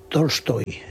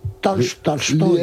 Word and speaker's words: Tolstoy: Es una A que tira hacia el O, pero que es A. – Una mezcla entre O y A. – O y A. – Tolstoy. – Porque las palabras que se escriben en O en ruso, pero van acentuadas, se pronuncian casi A.